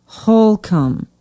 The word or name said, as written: holcombe